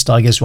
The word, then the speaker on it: Stigers: And I said that, and I cannot help but do this, to say Curtis Stigers rhymes with tigers.